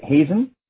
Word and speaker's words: Hazen: Just uh, I, did I say his name right? Hazen. – Absolutely. Yes.